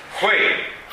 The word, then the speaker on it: Hui: Hui. Piccolo ripasso di pronuncia. La H è cata rosa e UI si pronuncia Wei.